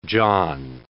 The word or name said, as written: John